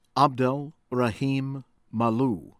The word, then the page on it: Abdel Rahim Mallouh: American English